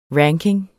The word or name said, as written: ranking